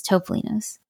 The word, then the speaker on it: Topolino's: So I googled it, and the appropriate Italian way to pronounce it is Topolino's.